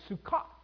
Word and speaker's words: Sukkot: And, well, the traditional name is Sukkot. Yeah, I pronounced that correctly.